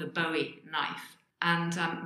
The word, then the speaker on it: Bowie: And today my conversation is with a lifelong David Bowie fan. So, hi. Welcome to All About You podcast. – Hello, Sheila. How are you? – I'm fine, thank you. So, my first question, David Bowie.